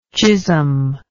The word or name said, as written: chisholm